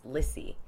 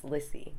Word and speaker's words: Lissie: So when I started performing, I was like, it's Lissie. Because it was spelled with S's, but, you know, people would call me Liz. Sort of from 18 on, I'm like, it's Lissie, because that's how it's spelled. But then if I go home, my friends are like, oh, Lissie.